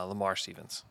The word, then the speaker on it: Lamar Stevens: Um, Lavar Stevens. I think I think that's who it was. Uh, Lamar Stevens. Oh my gosh, I've said his name wrong 500 times.